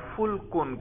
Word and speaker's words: فلک: فلکن کہتے ہیں، فا لام اور چھوٹا کاف، یہ آگے آ رہا ہے اگلی سطر میں ہی، کشتی کو کیا کہتے ہیں؟ فلکن،